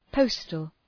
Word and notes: postal: ‘pəʋstəl

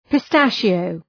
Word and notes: pistachio: pı’stæʃı,əʋ

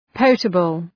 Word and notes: potable: ‘pəʋtəbəl

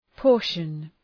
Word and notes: portion: ‘pɔ:rʃən

